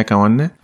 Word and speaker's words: Kawande: Enya Kanwane. Bis auf das N, das du ausgesprochen hast? – Ah, okay. Das ist ein stummes, in, also es ist Enya Kawande.